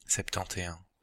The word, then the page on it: septante-et-un: /sɛp.tɑ̃.t‿e œ̃/; Belgique, Brabant wallon